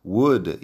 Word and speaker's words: would: Would y could, la L no suena.